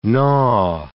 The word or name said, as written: gnaw